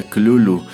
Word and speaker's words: Cthulhu: Sin embargo, a decir de Lovecraft, por la manera en que él lo explicó, en realidad una pronunciación más acertada sería Clulu, aunque pues de manera más eh, gutural, porque a fin de cuentas se supone que es una palabra eh, que originalmente pronunciaban criaturas que no son de este mundo.